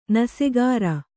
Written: ನಸೆಗಾರ

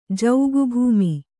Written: ಜೌಗುಭೂಮಿ

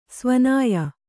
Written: ಸವಿನಯ

